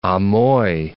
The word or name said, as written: Amoy